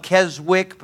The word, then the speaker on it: Keswick: The old Keswicks, spelled Keswick but pronounced Keswick, the old Keswick preachers, they'd say this.